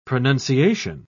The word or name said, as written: pronunciation